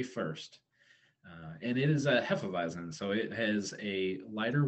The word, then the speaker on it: Hefeweizen: Trixie's Alibi Hefeweizen. Did I pronounce that right? – Perfect. – Oh, okay. Accidents happen. – You even got the V for the W.